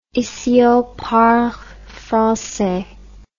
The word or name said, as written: ici on parle francais